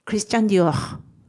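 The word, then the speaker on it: Christian Dior: ですので、これをフランス語で発音すると、クリスチャン・ディオール、クリスチャン・ディオールになります。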